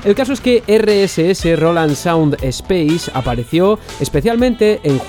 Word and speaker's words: Roland Sound Space: Eh, otro ejemplo lo encontramos en el sistema de audio Roland Sound Space, que creo que antes lo dije mal, es Roland Sound Space o RSS,